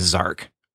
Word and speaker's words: Xarq: And its name is Zark. It's spelled X-A-R-Q instead of Z-A-R-K.